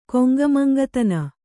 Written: ಕೊಂಗಮಂಗತನ

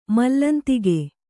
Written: ಮಲ್ಲಂತಿಗೆ

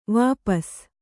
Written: ವಾಪಸು

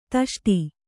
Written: ತಷ್ಟಿ

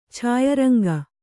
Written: ಛಾಯರಂಗ

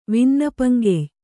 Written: ವಿನ್ನಪ್ಪಂಕೆಯ್

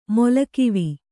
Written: ಮೊಲಕಿವಿ

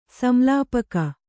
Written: ಸಂಲಾಪಕ

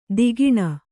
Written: ತಗಣ

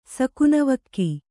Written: ಸಕುನವಕ್ಕಿ

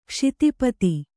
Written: ಕ್ಷಿತಿಪತಿ

